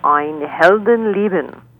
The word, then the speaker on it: Ein Heldenleben: They're also playing a Wagner piece, the Overture to the Flying Dutchman, and something called Ein Heldenleben, A Hero's Life from Richard Strauss.